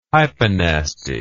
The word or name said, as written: hyponasty